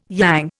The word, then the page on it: yang: Indian